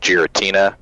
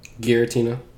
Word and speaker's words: Giratina: That's like right around there, and then they had the movie about, was it Giratina? – Giratina. – God damn it. Yep, I had a feeling I pronounced it wrong.